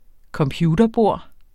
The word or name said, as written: computerbord